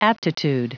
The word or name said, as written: aptitude